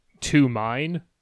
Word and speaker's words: Tomine: because he's Tomine is written like to mine, but it's a Japanese name, so it's when he pronounced Tomine.